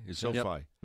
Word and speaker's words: SoFi: Is that pronounced SoFi? – SoFi.